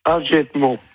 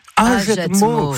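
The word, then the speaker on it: Hagetmau: Et le village où j'habite, ça se prononce Agetmo. – Agetmo.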